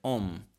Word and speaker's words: Om: La sillaba Om è scritta in tre parti, no? – che sono tre suoni, il suono A, U e il suono Ma. A e U diventa O, O e Ma diventa Om.